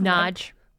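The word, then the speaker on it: Nagy: Yeah, they the screenwriter Phyllis, how did Nodge. – Phyllis Nodge, we just learned how to pronounce her last name.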